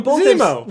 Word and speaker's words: Zemo: Vimo! Vimo! Zemo! – We've said this name wrong so many times.